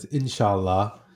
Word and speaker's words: Inshallah: I believe it's pronounced Insala. What does that word mean, and why is that word so important to you? – Yeah, it's Inshallah, and you know, it's an Arabic word that's used in so many different ways.